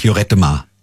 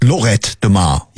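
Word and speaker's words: Lloret de Mar: Nee, noch nicht, aber ich glaube, es geht eine Woche nach Jorettemar. – Jorettemar, sagt er. Du meinst Lorettemar. – Ja, aber zwei L's werden da halt ausgesprochen wie J, ja? Es heißt ja auch Paella und nicht Paella.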